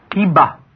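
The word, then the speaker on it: Jeba: Then we have the Arabic town name, Hiba, Hiba, which is spelled with a J, J-E-B-A, which, even though you don't pronounce it as a J sound, it's pronounced differently in Arabic, but this is biblical Giba, almost identically the same sound.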